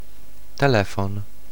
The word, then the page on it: téléphone: [te.le.fɔn]